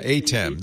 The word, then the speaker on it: ATEM: Yeah, because I noticed on the ATEM switch that you've been touting. Am I saying that correctly? – Yeah, ATEM.